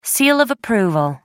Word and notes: seal of approval: American English

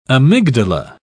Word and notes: amygdala: British English

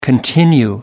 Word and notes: continue: American English

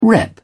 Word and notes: rep: British English

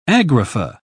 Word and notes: agrapha: British English

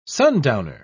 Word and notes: sundowner: British English

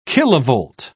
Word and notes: kilovolt: British English

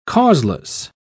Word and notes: causeless: British English

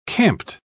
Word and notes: camped: British English

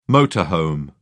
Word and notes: motor home: British English

